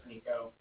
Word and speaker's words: Niko: Okay, yeah, like I said, my name's Nico. That's spelled N-I-K-O. And then the Bank of Cardiff is spelled C-A-R-D-I-F-F, as in Frank Frank.